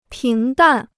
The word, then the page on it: 平淡: píng dàn